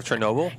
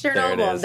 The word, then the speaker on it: Chernobyl: Are you thinking of Chernobyl? – Yes, Chernobyl.